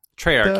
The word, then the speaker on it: Treyarch: it's Treyarch? – Treyarch, yeah.